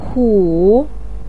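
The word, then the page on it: ku: tone 3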